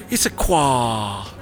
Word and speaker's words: Issaquah: And we're sitting down with Grace Larson, who is a dispatch supervisor at the Issaquah Police Department.